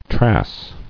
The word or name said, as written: Trass